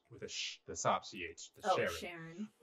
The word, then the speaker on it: Charon: And it's spelled Charon. Mm-hmm. So you want to say, like, Charon or something. – Charon. Yep. – But Sharon.